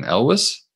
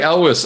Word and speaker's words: Elwes: How do you pronounce his last name? – Elwes. – Cary Elwes of Princess Bride and Robin Hood Men in Tights fame.